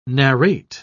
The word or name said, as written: narrate